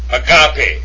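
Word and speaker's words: Agape (ἀγάπη): Agape, agape.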